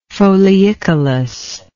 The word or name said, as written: foliicolous